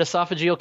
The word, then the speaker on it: esophageal: You had esophageal cancer. Is that how you pronounce it? – Yeah, so I had esophageal cancer.